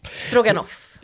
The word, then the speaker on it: Stroganoff: Det är ett ryskt namn. – Ja. – Ja, så är det. – Ja. Kul! – Och ska därmed uttalas med korta vokaler. Så. – Stroganoff.